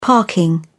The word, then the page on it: parking: British English